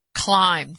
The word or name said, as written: climbed